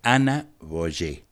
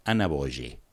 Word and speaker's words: Ana Boyé: Acuérdate, Ana Boyé, B larga, O, Y, E, con acento en la E, Ana Boyé.